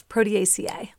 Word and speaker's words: Proteaceae: It's Proteaceae.